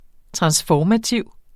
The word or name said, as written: transformativ